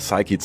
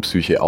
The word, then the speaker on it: Psyche: Und siehst du, du hast die Raumsonde Psyche zum Asteroiden Psyche auch wieder richtig ausgesprochen.